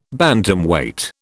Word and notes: Bantamweight: British English

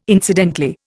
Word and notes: incidentally: Indian

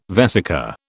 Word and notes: vesica: British English

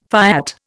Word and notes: fiat: Indian

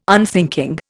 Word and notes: unthinking: Indian

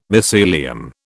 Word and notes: mycelium: British English

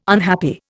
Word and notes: unhappy: Indian

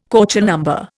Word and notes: Köchel number: Indian

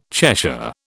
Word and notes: Cheshire: British English

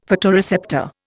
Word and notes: photoreceptor: Indian

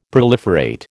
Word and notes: proliferate: American English

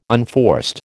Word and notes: unforced: American English

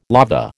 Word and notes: lava: American English

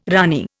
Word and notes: ranee: Indian

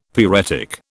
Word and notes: pyretic: British English

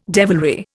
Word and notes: devilry: Indian

